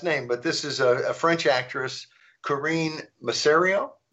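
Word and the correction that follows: Masiero: Massiero, yeah.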